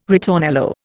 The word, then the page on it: ritornello: Indian